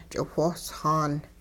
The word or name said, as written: ch'iwhoosxaan